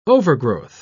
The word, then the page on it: overgrowth: British English